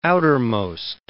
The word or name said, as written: outermost